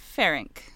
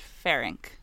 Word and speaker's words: Ferenc: I heard, so it was spelled the same way in everything. It's F-E-R-E-N-C. Ferenc. But... in some documentaries I was watching, they said Ferenc, but there's no E between the N and the C, and then others said Ferenc.